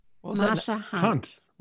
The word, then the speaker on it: Marsha Hunt: Say that name again. – Marsha Hunt. – Rhymes with stunt.